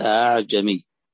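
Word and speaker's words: ءأعجمي: والتسهيل معنى أن تنطق الهمزة بين الحرف الذي شكلت به، فهنا بالفتح فبين الألف والهمزة فنقول أعجمي،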